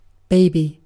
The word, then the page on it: baby: British English